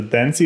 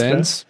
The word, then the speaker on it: Dance: Jetzt muss ich gerade noch mal kurz eingrätschen zum Charles Dance oder Dancy oder was gerade über Charles Dance. Dance. Ja.